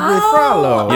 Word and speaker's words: Frollo: I don't know if you pronounce it Frollo or Froyo. – Or Frollo. – Frollo. – Frollo. It's probably Frollo.